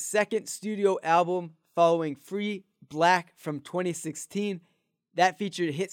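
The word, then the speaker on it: 6lack: Yes, it is spelled like that, but it's pronounced Black.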